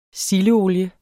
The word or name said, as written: sildeolie